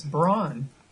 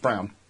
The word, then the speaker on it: Braun: I thought it was Brawn. – That's good. It's pronounced Brown. That's fine. – Okay. – It's B-R-A-U-N.